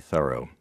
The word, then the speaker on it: Thoreau: Everything we know about how his name should be pronounced indicates that it should be Thoreau. It's the way it's always been pronounced in his hometown of Concord.